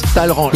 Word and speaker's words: Talleyrand: Qu'est-ce qu'on s'écrit Talleyrand, mais ça se prononce Talleyrand.